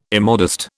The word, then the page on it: immodest: British English